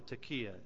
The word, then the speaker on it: taqa: The first one is the taka or the takia. It's spelled in the English T-A-Q-A, but it is often pronounced takia.